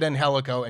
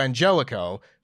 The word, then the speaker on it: Angelico: said Angelico and corrected himself to Angelico, so I'm going to go ahead and say that that's what his name is pronounced as now.